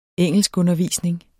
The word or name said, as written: engelskundervisning